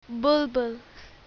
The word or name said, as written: bulbul